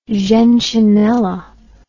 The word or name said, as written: gentianella